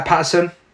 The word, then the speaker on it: Paterson: Bill Peterson, uh, Paterson. Wow, Bill Paterson, in the 1970s, aliens send a female android diplomat to Earth on a mission of peace.